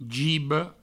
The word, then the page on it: giba: gibo